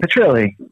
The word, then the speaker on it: Petrilli: Petrilli.